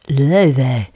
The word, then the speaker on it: Laufey: That's Icelandic Chinese jazz singer spelled L-A-U-F-E-Y but pronounced Leve.